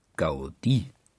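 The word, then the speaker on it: Gaudí: Betont man die Gaudi auf dem I, Gaudi, so ist sie männlich und ein katalanischer Architekt, der das Schräge dem Geraden und das Runde dem Eckigen vorzog.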